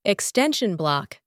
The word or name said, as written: extension block